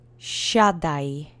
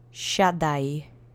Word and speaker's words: Siadaj: So the accent is on the first syllable. There's no special phoneme. So just repeat after me three times. Siadaj. Siadaj. Siadaj.